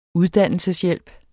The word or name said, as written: uddannelseshjælp